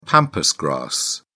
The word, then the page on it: pampas grass: British English